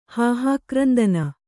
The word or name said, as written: ಹಾಹಾಕ್ರಂದನ